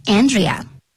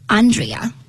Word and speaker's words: Andrea: 那讲到这个 Andrea 的名字，它其实有两个发音。我们可能老美会说 Andrea，那可能英国人会讲的是 Andrea。